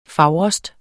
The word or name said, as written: fager